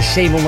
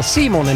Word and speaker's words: Simon: Ik moet het wel in het Engels uitspreken, het is geen Simon, maar Simon en Marijke.